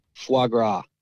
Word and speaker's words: foie gras: Foie gras. – Yeah. What? How do you say that? – Foie. F-O-I-E? – Foie?